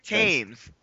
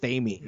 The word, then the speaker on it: Tames: Rick Tames. Yes. And they were really good. But, like, they never. – So it's pronounced Tames? Because I always thought it was, like, Thames. – No, Tames and Trout.